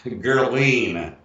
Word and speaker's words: Girlene: Girlene. – And what is it? It's spelled G-I-R-L-E-N-E.